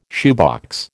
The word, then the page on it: shoebox: American English